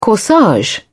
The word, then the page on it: corsage: British English